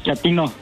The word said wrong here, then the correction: chatino: No, chatino. – Es como chato, ¿verdad? Chatino. – Sí, asunero, como chato, chatino.